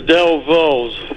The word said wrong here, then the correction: Dovells: Not saying it right. – That's all right. You know it's the Dovells, and I'll give it to you for that.